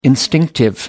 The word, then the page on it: instinctive: American English